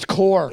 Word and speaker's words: corps: It's CORE. That's pronounced.